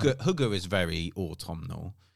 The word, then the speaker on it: hygge: Hygge, I think is how it's spelt, but it's pronounced hygge.